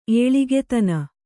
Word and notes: ಏಳಿಗೆತನ: Kannada

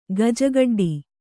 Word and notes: ಗಜಕಡ್ಡಿ: gaja kaḍḍi

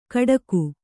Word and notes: ಖಡಕು: kannada

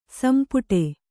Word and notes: ಸೊಂಪಿಡಿ: sompiḍi

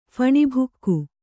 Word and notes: ಫಣಿಭೋಗ: phaṇi bhōga

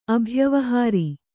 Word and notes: ಅಭ್ಯವಹಾರ: abhyavahāra

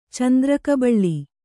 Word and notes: ಚಂದ್ರಕಬಳ: kannada; candra kabala

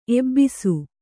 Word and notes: ಎಬ್ಬಿಸು: ebbisu

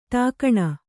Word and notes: ಠಿಕಾಣೆ: ṭhikāṇi

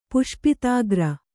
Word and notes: ಪುಷ್ಪಿತಾಗ್ರ: puṣpitāgra

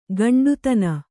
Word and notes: ಕೊಂಡಿತನ: kannada

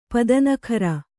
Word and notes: ಪತನಕಾರಿ: patanakāri

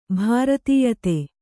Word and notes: ಭೃತ್ಯತೆ: bhřtyate; kannada

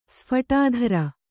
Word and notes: ಸ್ಫಟಾಧರ: kannada

